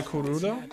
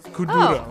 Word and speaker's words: Kuduro: Danza Kurudo? Kuduro.